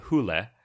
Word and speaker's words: Hole: So I was reading about the book series, and I guess Harry Hole, first of all, in the books, it's pronounced Harry Hule. But in this movie, they just say Hole, because I guess the character is based on a Norwegian town called Hule, Norway.